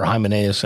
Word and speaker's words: Hymenaeus: Hymenaeus.